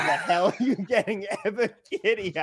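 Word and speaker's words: Ebiketie: but Ebikidi, right? I believe that's Arnold. Arnold Ebikidi. – Ebikidi. There we go.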